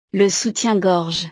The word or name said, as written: le soutien gorge